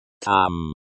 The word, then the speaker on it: Там: Там. Там, pronounced like там, with the sound as in father.